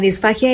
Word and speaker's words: disfagia: Pero ustedes están hablando de que la disfagia es la manera de cómo nuestros alimentos llegan a nuestro estómago.